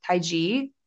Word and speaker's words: Taiji: And I believe it's pronounced Taiji, T-A-I-J-I in Japan.